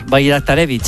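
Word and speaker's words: Bajraktarević: El primero es un bosnio del PSV que se llama, voy a decirlo tal y como se escribe, Esmir Bajraktarevich. – Sí, señor, Bajraktarevich.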